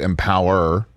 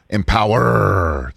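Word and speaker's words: Empowerrr: Yeah, two big pay-per-views this weekend, including the all-women's pay-per-view Empower. Did I say that right? Because there's three R's at the end. – You got to kind of roll your R's.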